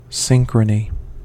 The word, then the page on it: synchrony: American English